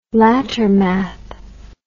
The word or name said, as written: lattermath